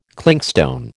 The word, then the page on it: clinkstone: American English